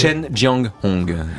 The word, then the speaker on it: Chen Jianghong: plus exactement par Cheng Jianghong, j'ai bien prononcé. – Chen Jianghong, c'est un peintre chinois dont alors les enfants connaissent son travail parce que il a beaucoup collaboré à l'école des loisirs.